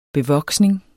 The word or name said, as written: bevoksning